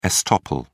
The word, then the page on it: estoppel: British English